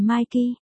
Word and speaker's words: Mickey: Tôi phát hiện, Mickey, đọc là Mikey, nên là khi Maki giới thiệu tên mình, Mickey đã nghe nhầm thành việc Maki đang gọi tên cậu ta.